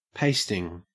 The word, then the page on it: pasting: Australian English